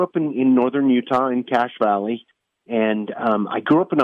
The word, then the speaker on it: Cache Valley: It's pronounced Cache Valley. The word cache in it, it's spelled different.